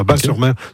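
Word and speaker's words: Batz-sur-Mer: La carte postale pour moi, c'est le bidule, et surtout la crêperie s'appelle le Dervin à Bas-sur-Mer, qui font les meilleures crêpes de la on prononce bas, le T ne se prononce pas, Bas-sur-Mer.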